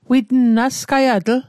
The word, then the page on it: witn: Mi'gmaq